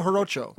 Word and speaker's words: Jarocho: Radio Jaracoa? – Radio Jarocho. – Jarocho. Oh, my pronunciation is horrible.